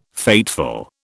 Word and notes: fateful: British English

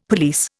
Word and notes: police: Indian

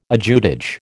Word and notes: ajutage: American English

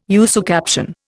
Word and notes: usucaption: Indian

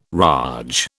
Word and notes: raj: British English

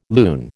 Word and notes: lune: American English

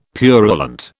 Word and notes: purulent: British English

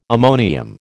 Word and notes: ammonium: American English

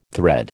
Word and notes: thread: American English

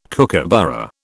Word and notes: kookaburra: British English

